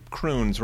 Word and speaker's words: Crunez: We called him cr- Croons, rhymes with prunes when Corey read his, uh, his name uh, on a previous uh, opening.